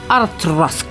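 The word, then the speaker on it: Auchroisk: Debido a la creciente demanda del JIB Rare Blend en 1974, Grand Met construyó la destilería de Ausroik, que se pronuncia así, Artrosk.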